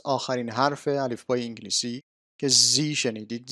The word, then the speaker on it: Z: زی تلفظ امریکایی هست. خانمی که داشت حروف رو میخوند امریکایی بود، بنابراین این حرف رو زی تلفظ کرد. تلفظ زد هم درسته. تقریبا بقیه کشورهای انگلیس زبان به جز امریکا همه این رو زد تلفظ میکنن. فقط امریکایی ها هستن که زی تلفظ میکنن.